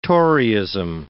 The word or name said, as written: Toryism